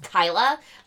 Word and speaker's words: Kyla: And then we've got Tracy Faraway, who played Kyla, which whenever I read how I wrote her name, I cannot not say Kayla. – Yeah, because it's spelled Kayla. And that they even make that a thing in the movie, is that, like, someone's like, oh, hi, are you Kayla? She's like, it's pronounced Kyla, like, all defensively.